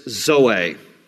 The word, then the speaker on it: zoe: But there's another Greek word for life, and that is zoe, Z-O-E. Usually, for a girl's name, it's pronounced Zoe. But in the Greek, the accent is on the last part, zoe, and it means life, and it means a fulfilled life.